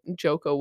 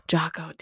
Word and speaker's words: Jocko: And I think that Joko Willink, I think that's how you say his name. – Jocko.